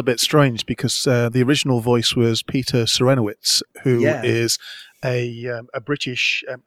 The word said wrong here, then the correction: Serafinowicz: Isn't it Serafinowicz? – I always pronounce it incorrectly.